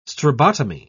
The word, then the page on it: strabotomy: British English